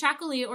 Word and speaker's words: Txakoli: So there's Chacolí or Chacolina, and that's spelled TXAKOLI and then NA.